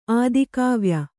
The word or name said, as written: ಆದಿಕಾವ್ಯ